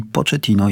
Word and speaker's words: Pochettino: Michale, otóż nazwisko rzeczy Mauricio Pochettino rzeczywiście jest nazwiskiem pochodzenia włoskiego, niemniej Mauricio jest Argentyńczykiem i nazwisko tutaj czytamy z hiszpańska, czyli Pochettino jest właściwą wersją.